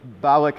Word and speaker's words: Balak: And so, and it's Balak is how you say his name.